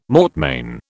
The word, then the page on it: mortmain: British English